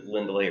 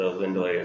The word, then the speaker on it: Ainulindalë: I assume it's because Ainu and Lindelay are two different stems. – Mm-hmm. – Like the Lindelay is from song. And Elvish tends to put the stress on the first syllable anyway.